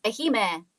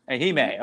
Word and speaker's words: Ehime: Now, you were born originally in Ehime, Japan. Am I saying that right? – Yes, Ehime. Yeah. Very small island. Ehime, Japan? – Yeah. – Ehime.